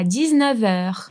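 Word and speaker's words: dix-neuf heures: O 9 em francês termina com F. Mas, ao encontrar uma vogal ou um H, a pronúncia vai ser de V. Por isso, 19h, ok?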